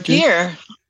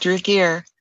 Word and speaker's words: Ragir: Is that how she pronounced it? Ragir. That's correct. Ragir.